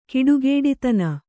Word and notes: ಕೆಡುಗುಡಿತನ: keḍuguḍitana

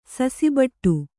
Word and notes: ಸಸಿಬಟ್ಟು: kannada

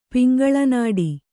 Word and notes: ಪಿಂಗಳನಾಡಿ: kannada

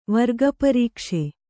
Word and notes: ವರ್ಗಪರೀಕ್ಷೆ: Kannada

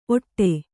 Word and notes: ಒಟ್ಟೆ: kannada

